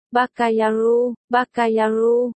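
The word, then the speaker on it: ba ca ya ru (バカヤロウ): Bát sát nha lộ, ba ga ya lu, bát sát, tiếng Trung đọc là, ba ga, gần giống tiếng Nhật là, ba ca, nghĩa là đồ đần, đồ ngốc, nha lộ, tiếng Trung đọc là, yá lù, gần giống tiếng Nhật là, ya ru, nghĩa là tiểu tử, city, hợp lại là, ba ca ya ru, nghĩa là hỗn trướng, hỗn đản, nôm na là mãng phu.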